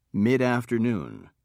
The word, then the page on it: midafternoon: British English